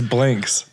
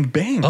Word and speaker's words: Blanks: I think we've been saying it wrong this whole time. Google is now saying it is Blanks. – Dang, I was thinking Banks.